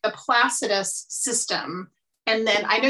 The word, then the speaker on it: Placidus: The Placidus system. – Yeah, of course. Yes, an awesome pronunciation.